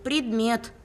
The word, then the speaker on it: предмет: А слово «бредмет». – Что? – Ты произнес это слово через «б». Бредмет. Дважды. Надо говорить правильно. предмет.